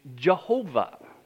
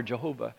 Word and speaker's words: Jehovah: And if we add the vowels, this is where we get the words Yahweh or the Latin pronunciation Jehovah. Yahweh or Jehovah.